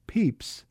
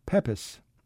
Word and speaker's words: Pepys: The accepted pronunciation today of his curiously spelled name is Pepys. Samuel himself definitely pronounced his name that way, as do the descendants of his sister Paulina. However, other branches of the family pronounce it Pepys.